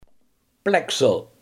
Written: plaksel

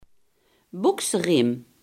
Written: broekriem

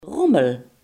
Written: rommel

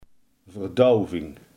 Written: verdoving

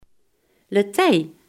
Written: draagbalk

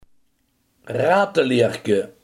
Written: herderstasje

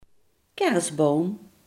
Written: kerstboom

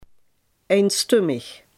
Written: eenstemmig

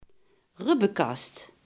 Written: borstkas

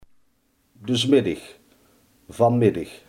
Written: vanmiddag

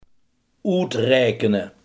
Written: uitrekenen

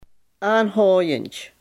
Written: aanhoudend

